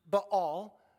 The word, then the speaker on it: Baal: And what we read there is that they, as they called out to the god of Baal, properly pronounced Baal, but we're maybe more familiar with Baal, so we'll use that.